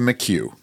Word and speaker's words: McHugh: Every listener has heard the name differently. In my mind, it was McCune. – The name is spelled capital M-C. Capital H-U-G-H, Marvin McHugh. – Marvin McHugh. All right. Well, she spelled it slightly different, but the same pronunciation, I believe.